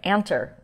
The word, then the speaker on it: Anter: and Vince Atler. I just mispronounced his name, Anter, Vince Anter, who he hosts a show that you can find.